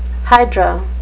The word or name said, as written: hydra